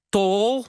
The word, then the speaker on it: Tawl: En dan Philip Dreugen, De Tol, moet je uitspreken, denk ik. Want het is T-A-W-L, hoe de Nederlandse taal bijna Amerika veroverde.